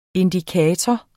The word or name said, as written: indikator